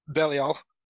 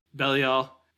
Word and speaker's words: Belial: And his advisor is known as Belial, I think it is. I forget how to pronounce it. – Yes, Belial. – Belial.